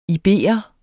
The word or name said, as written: iberer